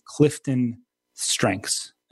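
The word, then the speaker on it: CliftonStrengths: And you actually did some coaching with me a couple of years ago because you are a certified coach using the Clifton strengths. Am I saying that correctly in terms that is, of what you're? That is correct.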